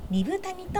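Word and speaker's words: 二風谷: 車でおよそ 2時間、里乃さん、原田くん、ラジオ講座を支えてくださっている関根健二さんが住んでいるアイヌの里、ビラトリ町二部谷にやってきました。豊かな自然、緑の森に囲まれ、猿川が流れる中、アイヌの伝統が色濃く残る地域です。二つの風の谷と書いて二部谷と読みます。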